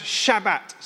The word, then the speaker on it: Shabbat: Now, in Hebrew, the word is pronounced Shabbat.